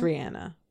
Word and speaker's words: Rihanna: Is actually how you heard. This is the correct pronunciation. – Rihanna? – It's Rihanna.